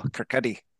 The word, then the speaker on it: Kirkcaldy: Adam Smith was born in a place called Kirkcaldy, but it's spelt like if you were a North American, Kirkcaldy. And there's the podcast that literally had one minute of him saying, Where was Adam Smith born? Kirkcaldy. No, Kirkcaldy.